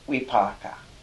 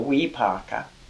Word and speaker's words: vipaka: The result of action is called vipaka. V-I, and V's are pronounced like W's. So vipaka. V-I-P-A-K-A.